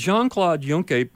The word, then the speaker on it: Jean-Claude Juncker: And then, this is funny, under the headline, Doing the Right Thing, we read, as Jean-Claude Juncker, is that how you pronounce it, Prime Minister of Luxembourg? – Yes.